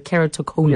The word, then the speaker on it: keratoconus: Am I saying that correctly? The keratoconus? – Yes, that's right.